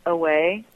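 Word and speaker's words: AWĀ: It's pronounced away, okay.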